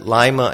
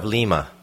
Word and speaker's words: Lima: So you pronounce it Lima instead of Lima? – Yes.